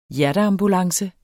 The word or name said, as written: hjerteambulance